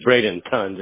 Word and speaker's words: Bradenton: In regards to the areas that you want to service, so of course, Bradentown. Is there any other towns nearby that you like to go to? – There's Bradenton, just so you know. Don't spell it town. Ton. – Oh, yeah, Bradenton.